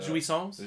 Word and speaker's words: jouissance: it's a french word right the, jouissance. Jouissance. Jouissance. Jouissance. Yes.